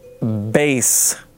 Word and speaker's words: Bass: We ask that you refrain from spoilers from the bass concert hall. Now, the reason why I said that is because in all caps, it says pronounced like the fish, not the instrument.